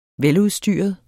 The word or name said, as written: veludstyret